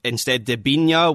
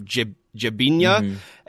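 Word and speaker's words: Debinha: Instead, De Binha, which I've googled my Portuguese pronunciation. I think you kind of mash the D E B together to get a sort of Jabinha.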